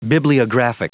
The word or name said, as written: bibliographic